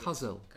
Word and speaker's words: Kozel: Kozel.